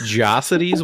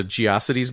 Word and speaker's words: Geocities: Is it pronounced Jocities? What the fuck was that? – It's Geocities. – It's Geocities, then.